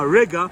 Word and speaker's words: Riga: Okay, that mall reminds me of a mall I was at in Riga, which rhymes with nigga.